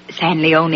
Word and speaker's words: San Leon: It's pronounced San Leone. – It ain't written that way.